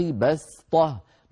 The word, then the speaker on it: بسطة: خلفاء من بعد قوم نوح وزادكم في الخلق بسطة، نطقناها لما قرأنا الصفحة بالسين.